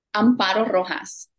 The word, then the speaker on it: Amparo Rojas: Amparo Rojas.